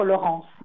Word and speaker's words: Laurens: Tandis que là, l'autre Laurence, le titre du film, c'est L-A-U-R-E-N-S. Moi, je pense qu'il faut le prononcer. L'autre, Laurence.